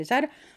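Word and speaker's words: Sarah: Τώρα μπορεί να μην τον πω και σωστά αυτόν, αλλά νομίζω ότι προφέρεται Ζέσελμαν, αν η Ζάρα μου, είναι Σάρα γράφεται, αλλά προφέρεται Ζάρα, αυτός ο συγχαμένος προφέρεται Ζέσελμαν, με δύο νι στο τέλος, με δύο σου στη μέση, κατάλαβες, Ρόμπερτ, Ρόμπερτ.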